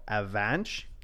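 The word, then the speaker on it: Avanc: So it has it's a Welsh name. So pronunciation is A-V-A-N-J-K. So Avanch. – Oh. – I'm just gonna say Avank.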